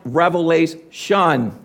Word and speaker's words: Revelation: it's pronounced Revelation. There's no S on the end of that word.